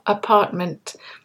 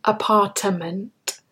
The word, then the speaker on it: apartment: Now, in this context of going away for the weekend and booking some accommodation, I wanted to mention the pronunciation of apartment. We don't say apartment. When you do that, you're adding an extra letter. You're actually adding an extra syllable. So listen carefully. We say apartment. Apartment.